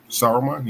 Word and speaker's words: Saruman: Does it have the L in it? – No. – Is it Saruman? – Saruman, yeah. – Okay, that one. Sauron and Saruman are very, very similar.